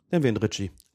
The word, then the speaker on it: Ricci: Im Fernsehen wurde er Ricky ausgesprochen. Ich würde ja sagen, es ist wahrscheinlich von der Wortherkunft hier ein italienischer Name, dann wäre es Ricci.